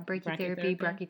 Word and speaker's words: brachytherapy: And those are the brachytherapy, if I said that correctly. – Yeah, brachytherapy.